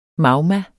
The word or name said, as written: magma